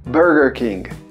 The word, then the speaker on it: Burger King: Esse i no final a gente vai cortar porque você está querendo a pronúncia do inglês. Então se você for falar, então Burger King, Burger King. Lembrando também que o som do U não é de Burger King, é Bur, Burger, Mesma dica que a gente deu antes do hamburger.